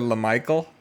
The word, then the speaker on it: Lamical: Oh, I was pronouncing the guy Lamical last year, but it was actually Lamichael. – Oh, that's right. That's right. – Because it's spelled L-A-M-I-C-A-L.